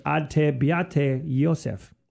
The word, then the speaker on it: ad te beate Joseph: To you, O blessed Joseph, and again, I'm going to butcher the Latin, but ad te beate Joseph.